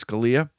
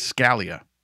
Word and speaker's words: Scalia: The Italian pronunciation is Scalia. The New England pronunciation is Scalia.